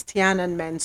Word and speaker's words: Tiananmen: I think it's Tiananmen Square, that's the pronunciation, that happened in 1989.